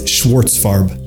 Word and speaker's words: Schwartzfarb: How do you say it? Amos Schwartzfarb.